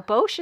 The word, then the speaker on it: Bosch: Bosch is how you pronounce the appliance.